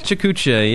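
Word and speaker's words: PechaKucha: Is it Petchakucha? The way you pronounce it? Petchakucha, yeah. Some people are familiar with it, some people aren't, but it basically just means short talks, short presentations.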